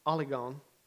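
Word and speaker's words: oligon (ὀλίγον): In the ESV, they leave out a Greek word that I think is important, and it's pronounced oligon, and it means few.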